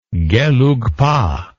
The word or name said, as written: gelugpa